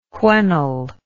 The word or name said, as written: whenll